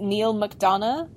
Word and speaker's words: Neil McDonough: So, is it Neil McDonough is what how you'd say his name, I guess? – Yep.